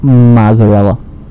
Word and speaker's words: mozzarella: You pronounced it with a T. Where's the T? – Mozzarella? – Mozzarella. – Mozzarella. – Where's the T? – The two Z's make that sound, bro.